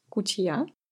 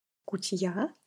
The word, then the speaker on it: кутья: Сочево, да, во втором слоге мы пишем «и», его еще называют «кутья», «кутья», ударение на «я», или «калива».